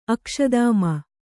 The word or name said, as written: ಅಕ್ಷದಾಮ